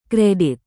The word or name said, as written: kredit